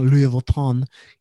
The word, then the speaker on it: Louis Vuitton: He ran up the room and food bill of almost $3,500 plus $7,500 in limo charges and fraudulent Louis Vuitton luggage purchases. I like how I can pronounce Louis Vuitton. I don't know why you said it like that, but correct.